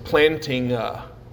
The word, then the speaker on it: Plantinga: Plantinga. Like planting a at the end. So like planting a church, planting a Alvin.